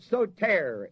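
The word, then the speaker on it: sōtēr: The word Savior in the text is the word soter, S-O-T-E-R, with a long O and a long E.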